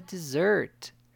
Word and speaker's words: dessert: Cuidado ao pronunciar a palavra dessert, ainda que a palavra possua dois S's. A mesma tem o som de Z.